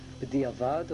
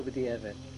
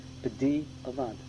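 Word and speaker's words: B'diavad (בדיעבד): B'diavad or B'dievet? B'dievet doesn't exist. It's not a real word. B'diavad.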